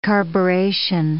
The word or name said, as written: carburetion